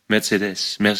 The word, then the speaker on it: Mercedes: Und musst auf die letzte Silbe. Mercedes.